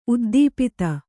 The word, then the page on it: ಉದ್ದೀಪಿತ: Kannada